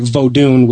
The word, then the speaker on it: Vodun: because that really did paint voodoo or Vodun, which is the a proper pronunciation of it.